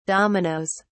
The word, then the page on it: dominos: British English